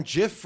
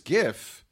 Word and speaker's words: GIF: People debate on GIF versus GIF. – Which side of the fence do you fall on? – Oh, you see, I knew you'd do that. – I think you're a soft G guy. – I'm a hard G.